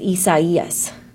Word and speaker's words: Isaias: It looks kind of like Isaias, but it's not Isaias, it's Isaias.